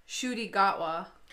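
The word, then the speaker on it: Ncuti Gatwa: Shuti Gatwa. – Holy fuck. I'm so sorry. – Yeah. The NC in his name is enunciated like a soft sh.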